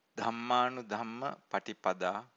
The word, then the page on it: dhammanudhamma patipada: Pali